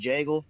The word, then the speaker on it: Jagel: Like, it rhymes my last name, Jagel, with a J.